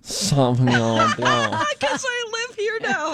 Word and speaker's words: Sauvignon Blanc: Uh, if you do want to pronounce it the French way, the genuine French way, you'd say Sauvignon. Sauvignon Blanc.